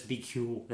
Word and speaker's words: BQL: And it's spelled BQL, but it's Bikul.